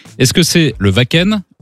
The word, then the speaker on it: Wacken: Eh oui, le Wacken, évidemment.